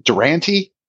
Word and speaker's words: Durante: Eric is going to have to check my work here because is it Durante? – Yes, that is good.